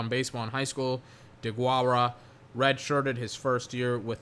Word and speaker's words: Deguara: The third round pick, Joshua DeGura. I mean, I don't know anything about this guy. What does he do exactly? DeGuara. Apparently that's how you say his name.